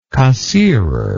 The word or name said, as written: cassirer